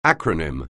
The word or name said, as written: acronym